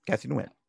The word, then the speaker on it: Nguyen: Kathy Nguyen. Did I say that right? – Yeah, Kathy Nguyen.